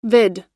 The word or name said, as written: vid